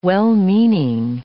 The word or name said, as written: well-meaning